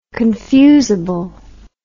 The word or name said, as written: confusable